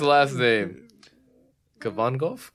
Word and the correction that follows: Kavanaugh: Oh Jesus! You're butchering that so hard. It's Kavanaugh.